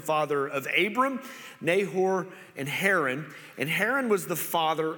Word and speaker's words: Haran: And then Haran, okay, which could also be pronounced Aaron. It's kind of a spelling of, but Haran, okay.